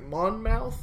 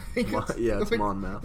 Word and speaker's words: Monmouth: And after his birth, the family soon moved to, like, Monmouth. – Monmouth. – I think it's how you say it. It's how it's spelled. Yeah. Monmouth, Illinois. I was going to be like, Mammoth, Massachusetts.